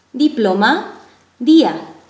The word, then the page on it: diploma: spanelstine